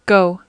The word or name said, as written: go